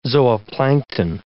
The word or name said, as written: zooplankton